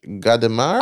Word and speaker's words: Gadamer: Season 8, Episode 91, Jenny Zagrino and Hans-Georges Gadamer. – I was blown away you pronounced his name right off the bat because I forgot to include the pronunciation and you nailed it.